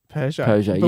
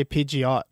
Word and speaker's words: Peugeot: Peugeot. But when I was a kid, I used to say Pidgeot, like that Pokemon, because it was kind of spelt similar.